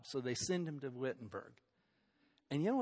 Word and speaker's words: Wittenberg: There's a new university starting over in the town of Wittenberg is how it should be pronounced.